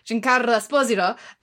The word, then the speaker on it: Giancarlo Esposito: But I thought that they bungled our last stuff with, and I'm going to say his name right now that I know I've been saying it wrong for 10 years, Giancarlo Esposito.